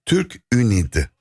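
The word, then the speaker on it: TÜRK-ÜNİD: Peki efendim, siz halen devam eden Türk Ünid, doğru mu telaffuz ettim, danışma kurulu üyesiniz Köln'de. – Evet. – Doğru mu telaffuz ettim? – Doğru telaffuz ettiniz.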